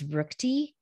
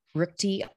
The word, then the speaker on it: Ruchti: My last name is Ruchti, terribly hard to pronounce or spell, but it's Cynthia Ruchti, R-U-C-H-T-I .com.